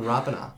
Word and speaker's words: Rapana: Everyone used to say Rapana. Yeah. And it's like, no. It's like Jordan Rapana.